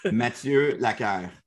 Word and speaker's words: Mathieu Laquerre: Mathieu Laquerre.